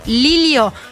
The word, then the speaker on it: Lillo: Miguel Lilio, se pronuncia Lilio con Elie y no con Ya, que yo dije Miguel Lillo.